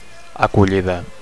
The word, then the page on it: acollida: Catalan